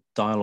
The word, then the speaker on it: Dyle: Talk us through the early stages of the engagement along the Dyle or Deal, depending on your pronunciation, river.